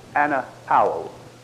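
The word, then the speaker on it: anapao (ἀναπαύω): The word for rest is anapao. The A-U is pronounced like in kraut. Anapao.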